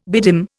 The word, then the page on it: bedim: Indian